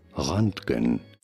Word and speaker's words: Roentgen: In my defense, it is spelled Roentgen, R-O-E-N-T-G-E-N. But for the record, the correct pronunciation of the name of the guy who discovered X-rays is Roentgen.